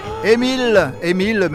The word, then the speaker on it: Amiel: Émile, mais ça s'écrit Amiel, mais Émile Cartwright,